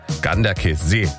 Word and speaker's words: Ganderkesee: Herzlichen Dank nochmal für die Aussprachetipps, die schnell reinkamen von unserem Radioarzt Jürgen in Gorch, der mir gleich geschrieben hat, Ganderkesee wird das ausgesprochen.